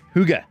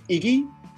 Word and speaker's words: hygge: Hygge. Am I saying that right, Mike? – Listen, I've heard Iggy, I've heard hockey, I've heard ick. So I think you deserve a solid 7 on that one.